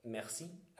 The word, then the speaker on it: Merci: or Merci, as uh, pronounced in French, obviously.